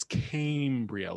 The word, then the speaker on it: Cambria: It is, I believe I'm right now, I thought it was Cambria and I got corrected by some very friendly fans of the podcast. – Wow. – And it's a, I believe it's a long A. It's Cambria, like Cambridge.